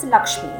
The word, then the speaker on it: LXME: And her most recent venture and passion is Lakshmi, which is spelled as L-X-M-E.